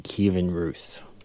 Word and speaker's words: Kievan Rus: Kievan Rus.